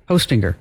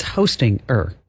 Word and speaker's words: Hostinger: H-O, like Santa, ho, and then stinger. – Hostinger. – Like a bee has a stinger. – But it's Hostinger. – Yeah, that's just how you spell it. – But it's like hosting, and then you put an ER at the end.